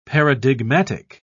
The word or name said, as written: paradigmatic